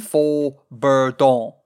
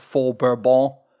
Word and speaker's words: fauxbourdon: It's the music thing I talked about a second ago Faux-Bourdon, F-A-U-X-B-O-U-R-D-O-N, not Faux-Bourbon, Faux-Bourdon. And I'm assuming it's pronounced all French-like.